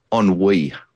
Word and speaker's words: ennui: And I think there's a deep sense of, wait for this, ennui. I think I pronounced that right.